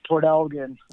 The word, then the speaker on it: Port Elgin: It's actually pronounced Port Elgin.